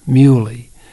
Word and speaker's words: muhly: It's been a great summer for the grass called bush muley. Muley is spelled M-U-H-L-Y.